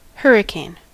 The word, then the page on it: hurricane: /ˈhɝ.ɪˌkeɪn/